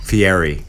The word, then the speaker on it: Fieri: Guy Fiore, big fan of the show. Here in person. – Fieri, Kat. Fieri. Fieri is the traditional Italian pronunciation.